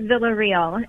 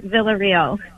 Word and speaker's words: Villarreal: Yeah, so in English, it's Jennifer Villarreal. And in Spanish, I even have a hard time with it. So let's just say Jennifer Villarreal.